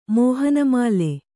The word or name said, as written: ಮೋಹನಮಾಲೆ